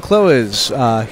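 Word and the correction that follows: Clowes: My name is pronounced Klaus, actually. – Klaus, I'm so sorry.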